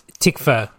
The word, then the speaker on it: Tom Tykwer: Tom Tykwer. – Tykwer.